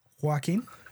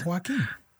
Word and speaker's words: Joaquin: Joaquin. That's his name. Joaquin.